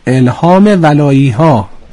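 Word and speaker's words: الهام ولایی‌ها: الهام ولایی ها، بسیار عالی، بسیار عالی. اصلا دقیقاً تلفظش رو شما درست میگید دیگه، معمولا ولایی میگن، ولایی درسته؟ درسته، به ما اینجوری میگن.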